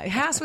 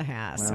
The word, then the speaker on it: Hass: Hass with class. Is that what you said? I need to start using that because people can never pronounce my name. And I'm always like, well, just remember pain in the Hass.